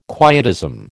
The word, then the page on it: quietism: American English